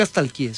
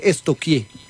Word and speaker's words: estalquear: Porque no se dice nunca estalquié, se dice estoquié. – ¿Estoquié? – Sí. Pregúntele a la profe Vera. – Estamos estoquiando. – Sí, estoqueando. Yo, ay, estoqueando. Pero se escribe estalquear. Es estoquear.